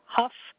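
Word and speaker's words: Hough: It's spelled the same way, and some call it Ho Street, some call it Huff Street. So it's H-O-U-G-H. I'm going to say Ho.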